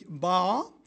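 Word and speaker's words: Ba (八): Also Behälter heißt Chao, Person heißt Kao, 8 spricht sich Ba und Schiff heißt demzufolge Chuan. Ich hoffe, das ist einigermaßen richtig und nicht zu viele Chinesen hören mir jetzt zu. Chao plus Kao plus Ba macht Chuan.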